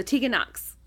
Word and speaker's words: Tegan Nox: uh, so Tegan Nox.